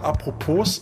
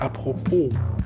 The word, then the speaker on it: apropos: Und ähm, das Wort apropos ist an sich falsch ausgesprochen. Es heißt eigentlich apropos. Das S wird nicht mitgeschrieben. Äh, gesprochen.